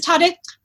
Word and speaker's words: Tade: Is it Tade or does anyone know how to pronounce it? It is Tade.